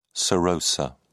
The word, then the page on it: serosa: British English